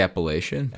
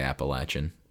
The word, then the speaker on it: Appalachian: I say Appalachian, but I think they say Appalachian.